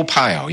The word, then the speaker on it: Arpaio: your neighbor Joe Arpaio, sheriff, if I pronounced his name right. – Joe Arpaio, yeah.